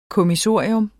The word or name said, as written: kommissorium